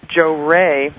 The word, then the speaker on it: Johrei: And one of the things I'm involved in is called Jo-Rei. It's from Japan. It's spelled J-O-H-R-E-I.